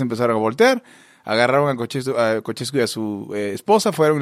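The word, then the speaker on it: Ceaușescu: Ceausescu, ok.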